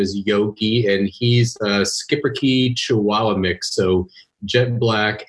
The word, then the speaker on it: Schipperke: Is, first of all, it's a horrible American pronunciation of what should be, I believe, Schipperke, which is for a little skipper.